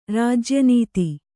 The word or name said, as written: ರಾಜ್ಯನೀತಿ